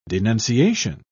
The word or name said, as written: denunciation